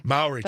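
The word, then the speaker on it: Māori: And if you don't know what we're talking about, this is, I want to pronounce it correctly, the Mora tribe? – Maori tribe. – Thank you for correcting me.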